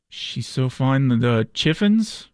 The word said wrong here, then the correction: Chiffons: How about Chiffons? The Chiffons, dude? The Chiffins? The Chiffins? – Pronunciation.